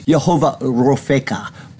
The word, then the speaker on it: Jehovah Rofeka: Actually, it's Jehovah Rofeka, if you were to pronounce it properly, but the word is Rapha, or healing.